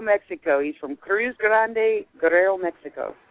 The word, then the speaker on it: Guerrero: I should say it properly, Guerrero.